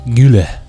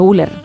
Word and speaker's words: Güler: Creo que Arda es Arda Güler. – Ah, Güler. Ah, Güler. – ¿Y cómo he dicho? Güler.